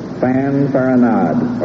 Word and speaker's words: fanfaronade: P-O-L-T-R-O-O-N. – Yeah, that was Gail Meyer in Bartlett, Tennessee. – Fanfaronade or fanfaronade, the French word meaning a swaggering, empty boasting, a bluster, fanfare. Fanfaronade or fanfaronade.